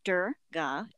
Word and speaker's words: Durga: So the name Durga, it's actually pronounced Durga in Hindu.